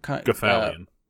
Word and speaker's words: Gothalion: Gothalion.